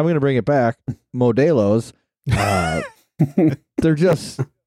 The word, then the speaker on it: Modelo: Well, I even know Shred, our buddy Shred, has been pounding nothing but Modelo's.